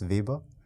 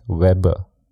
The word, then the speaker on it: Weber: In English, you are saying it right. – Yeah. How do you say that in German? – Max Weber. – Weber. Yeah. So in English, typically it's Weber.